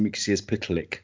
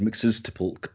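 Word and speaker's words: Mxyzptlk: The most common pronunciation is Mr. Mixius Pitalik. I've also heard Mixes Tipulk, but hey, you know, it's a very strange word.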